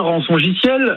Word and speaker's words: rançongiciel: Alors, on dit un rançongiciel, un ransomware, si on est anglophone.